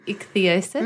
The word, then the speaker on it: ichthyosis: So you have a condition, I'm not telling you what you have, but called ichthyosis.